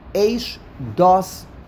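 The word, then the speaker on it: Eish, Dos (אשדת): Eish, Dos, Lomo. It's not Eish, Dos. It's two words. It's spelled as one word, but it is pronounced as two words.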